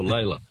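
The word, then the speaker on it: Lalor: Oh, Lawler. Or Layla. Layla. Yeah. Oh. Lawler's how it's supposed to be pronounced, but Layla's the other way.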